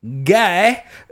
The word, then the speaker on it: Ghai: It's spelled S-I-M-A-R-T, that's Smart, and then the last name is Guy, spelled G-H-A-I.